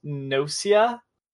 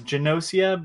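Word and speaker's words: Gnosia: The other game that's relevant, I'm not going to keep bringing up the same stuff I play every week, is, I want to say it's pronounced Nosia, but with a G in the front, because it's not G-E. So it's, I don't think it's pronounced Genosia, but that's a new game that came out from Playism on Switch.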